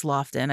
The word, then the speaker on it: Laughton: Laughton demanded that, or is it Lofton? I think it's Lofton. I've been saying it wrong.